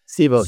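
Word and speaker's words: Civo: One called like Sibo or Kaibos, not how to pronounce that. – Sibo. – So Sibo, yeah.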